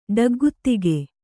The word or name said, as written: ಡಗ್ಗುತ್ತಿಗೆ